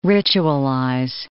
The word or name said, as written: ritualize